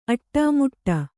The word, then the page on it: ಅಡಿಮುಡಿ: aḍimuḍi